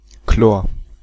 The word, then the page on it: Chlor: /kloːɐ̯/